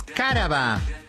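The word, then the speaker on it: cáraba: Vamos a decir la primera palabra de esta mañana es caraba. – ¿Eh? ¿Cómo? – Caraba, es esdrújula, es con acento en la A.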